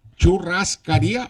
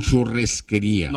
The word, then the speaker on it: churrascaría: Churrascaría, exactamente, así se pronuncia. – ¿Es churrascaría o churresquería? – No, churrascaría. – De churrasco.